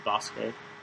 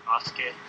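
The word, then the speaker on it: Basuke (バスケ): So it's spelled basket, but I think it's pronounced Basuke. – Basuke.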